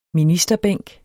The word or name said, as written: ministerbænk